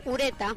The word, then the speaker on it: Ureta: ¿Lo he dicho bien? – Ureta. – Ureta. – Es mi apellido, ¿verdad? Sí. – Pues le he dicho fatal, entonces. Te he puesto una S, Ureta.